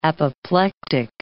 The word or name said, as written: apoplectic